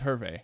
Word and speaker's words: Hervé: It's not how you say it. – Hervé? Javier? – No, it's Hervé. – Oh, it is Hervé.